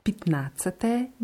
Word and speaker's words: пятнадцатое: Das Jahr nach dem P bei Pitnazate wird auch eher wie I ausgesprochen. Pitnazate Cabria.